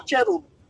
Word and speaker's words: Cherub: Kerubin, pare. Cherub. Tama ba yung pronunciation oh. ko? – Cherub o Kerub? Oh, Cherub. Cherub.